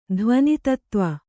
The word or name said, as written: ಧ್ವನಿತತ್ತ್ವ